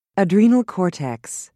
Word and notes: adrenal cortex: British English

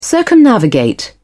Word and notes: circumnavigate: British English

